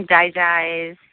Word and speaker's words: DiGize: The one other oil that I just remembered too, I don't know, is it Digize, or I've heard it pronounced a couple different ways. – Mm-hmm. Digize, yeah.